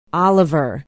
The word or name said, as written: oliver